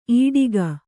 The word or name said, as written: ಈಡಿಗ